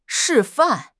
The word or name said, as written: 示范